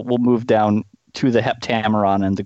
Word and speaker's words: Heptameron: The Heptameron. Did I say it properly? – Mm-hmm. Okay. – The yes. Heptameron.